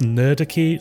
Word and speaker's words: Nerdarchy: They recently partnered up with, I think it's pronounced Nerdarchy, it's supposed to be like Anarchy, but for nerds,